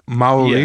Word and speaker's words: Maori: So you pronounce it Maori. Yeah.